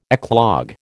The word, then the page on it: eclogue: American English